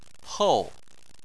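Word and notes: hou: tone 4

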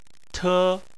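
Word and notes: te: tone 1

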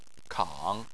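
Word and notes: kang: tone 3

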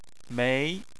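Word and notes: mei: tone 2

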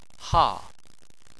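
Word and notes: ha4: tone 4

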